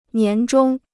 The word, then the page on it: 年终: nián zhōng